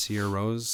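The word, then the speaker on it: Sigur Rós: Sigurros, but it's pronounced Sierra Rose.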